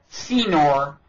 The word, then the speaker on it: Senor: How do you pronounce his last name? Senor. I keep wanting to put a tilde over the end and call him Senor.